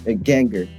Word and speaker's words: Ganger: G-A-N. Ganger. So I usually say gangster without the S-T.